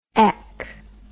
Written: ech

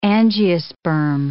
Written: angiosperm

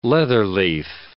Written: leatherleaf

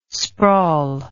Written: sprawl

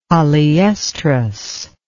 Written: polyoestrous

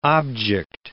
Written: object

